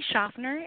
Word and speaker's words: Schaffner: Tell me how you say your name. Is it Schaffner? – You know, I say Schaffner. It's my married name, but that seems to be the consensus.